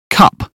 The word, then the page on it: cup: British English